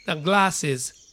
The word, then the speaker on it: glass: glasses, glasses. これがメガネです。 grass というのは芝生のことです。 glass, grass と違います。 L で言う glass, ラ、ラ、ラ、ラ。